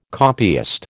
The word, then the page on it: copyist: American English